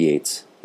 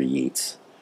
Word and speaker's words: Yeats: Their both names are pronounced the same, but spelled differently. Both Yeats, neither Yeats.